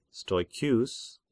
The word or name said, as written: Stoecheus